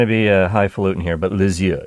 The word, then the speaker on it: Lisieux: But you know, Lisieux, that's how you say it.